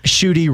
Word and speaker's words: Schutte: Josh Schutte rhymes with booty.